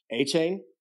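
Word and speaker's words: Achane: Oh, Achain? – Achain.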